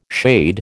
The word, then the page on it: shade: American English